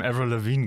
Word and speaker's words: Avril Lavigne: In dem Avril Lavigne Game? Oder wie man die ausspricht? Ja, so spricht man die aus.